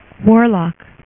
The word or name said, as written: adamwarlock